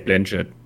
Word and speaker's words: Blanchett: Ähm, auf jeden Fall, wir haben Leonardo DiCaprio, Jennifer Lawrence, Timothy Chalamet, Ariana Grande, äh, Meryl Streep, Chris Evans, Jonathan Hill, Kate Blosch. Spricht man die Blochet? Oder einfach Blow? – Kate Blanchett.